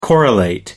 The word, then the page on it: correlate: American English